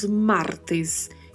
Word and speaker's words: martes: Pronúncia específica de martes, lembrando do R vibrato e da letra E sempre com o som mais fechado, né? Um som de E. Então falamos martes e miércoles miércoles, sempre com este som do E mais fechado.